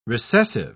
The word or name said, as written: recessive